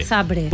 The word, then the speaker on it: Zabrze: Zabrze.